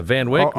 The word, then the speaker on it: Van Wyck: I'm going to say Van Wyck. I know you say Van Wyck. – Really? – Yeah, Van Wyck.